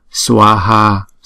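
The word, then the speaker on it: svāhā: Hai chữ A đều có dấu gạch ngang trên đầu, cho nên chúng ta phải đọc hai nhịp, đọc rõ ra là chữ swaha.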